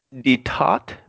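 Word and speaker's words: détente: Let me see if I can find a pronunciation. Here we go. French pronunciation. – Oh, we guessed that right. – Detot, meaning relaxation. – Detot? Okay. – D-E-T-A with a like mark over it. And a T.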